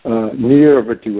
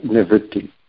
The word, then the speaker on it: Nivritti: but the actual Sanskrit is Nivritti, not Nirvritti. And a lot of people say it wrong. But if you look at all the Prabhupada's written works, it's always Nivritti. There's no R in there after the N-I.